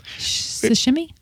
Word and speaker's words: sashimi: Sashimi? I think it's sashimi, isn't it? How do you say it? – The I sound is longer. – Oh, okay. Well, it's sushi. – Or the E sound. Sashimi.